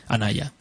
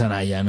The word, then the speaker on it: Anaya: Y el personaje principal lo hace Elena Maya. Anaya. Siempre lo digo mal, el nombre. Es como los libros, ¿no? No, sí. Es Anaya, no es como la Carmen Amaya, ¿no?